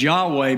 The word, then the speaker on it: Yahweh: In fact, we pronounce it as Yahweh, but if you look at this jumble of Hebrew letters, it is unpronounceable.